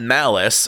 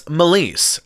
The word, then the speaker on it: Malise: It's not Malice, as in my boss has malice for the receptionist. It's Malise, as in Malise is up next month.